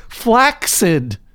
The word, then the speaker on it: flaccid: But I would appreciate it if the court would recognize it's supposed to be pronounced flaccid, as in accident or accelerate.